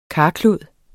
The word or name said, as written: karklud